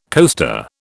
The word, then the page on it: coaster: British English